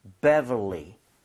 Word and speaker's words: Beverly: So 앞에 B랑 중간에 들어가는 V랑 구분하셔서 Beverly 요걸 먼저 연습하셔야 돼요.